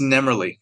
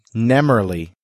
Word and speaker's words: Nemerle: And that brings us to Nemerle. Is that how we pronounce it? – It's Nemerle. – Nemerle.